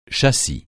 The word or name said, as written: châssis